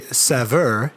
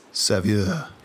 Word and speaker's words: saveur: It would be saveur is probably the best English pronunciation. – Oh, that's English. Yeah. – And we're both wrong. – But if you're going with the French, it'd be saveur.